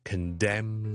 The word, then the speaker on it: condemn: 有 autumn 啦，column 啦，condemn 啦，都系 silent n sound。